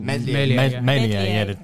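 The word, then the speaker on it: Meslier: Is it Melier. The S is silent.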